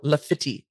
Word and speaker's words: Laffitte: My brain went Lafayette, but it's spelled L-A-F-F-I-T-T-E. So Lafitte is what we're going with.